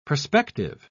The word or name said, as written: prospective